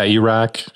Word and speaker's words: Iraq: I think you pronounce it Iraq, right? – Yeah, Iraq, sure. – Iraq, okay, yeah.